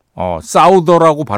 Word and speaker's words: Souther: 다큐멘터리를 보는데 Souther라고 발음을 하더라고요.